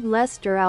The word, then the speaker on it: Leicester: So, wie spricht man denn das aus? Los, komm, erzähl mal. Wo ist das? Hier ist das. Los, erzähl mal. – Lord Leicester aus Manchester.